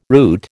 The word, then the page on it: route: American English